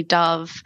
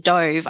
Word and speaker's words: Dove: So it's actually a double pun with my name as Ollie Dove. I study birds, but if you mispronounce it Dove, I actually study how birds dive.